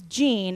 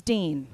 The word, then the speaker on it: Jean: It's James Jean. It's not James Dean.